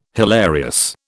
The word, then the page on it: hilarious: British English